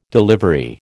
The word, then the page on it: delivery: American English